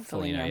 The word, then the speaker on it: Foligno: Nick Foligno? – Foligno, yeah. – I say his name wrong every time. I say every name wrong. – Like Lou Ferrigno.